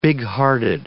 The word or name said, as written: bighearted